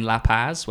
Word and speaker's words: La Paz: It's like playing Bolivia away in La Paz, where you're at that altitude and teams just never win in La Paz.